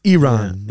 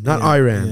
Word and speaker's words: Iran: And the reporter said, well, first of all, you pronounce our country's name wrong. It's Iran, Iran.